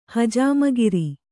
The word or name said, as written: ಹಜಾಮಗಿರಿ